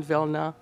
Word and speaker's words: Vilna: Now, I always refer to it as Vilna because that's the Yiddish pronunciation.